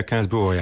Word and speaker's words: Gainsbourg: So the song from your youth is this one by Serge Gainsbourg. That's how you pronounce it, isn't it? – Yeah, Gainsbourg, yeah. – I would have said Gainsbourg, but it's Gainsbourg.